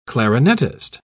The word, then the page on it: clarinetist: British English